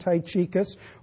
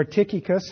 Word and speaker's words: Tychicus: When I send Artemis to you and Tychicus or Tychicus, however you want to pronounce it, my Bible dictionary pronounced it both ways, be diligent to come to me at Nicopolis, for I have decided to spend the winter there.